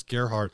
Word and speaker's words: Gerhard: He says, pronounced it like Gary Hard without the Y. So that's Gerhard.